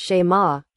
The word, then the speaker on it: shema: In the LXX, the Old Testament used by my son when he was down here, hupakoo is often used to translate the Hebrew, shema, pronounced shema, hear and believe.